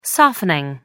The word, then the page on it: softening: American English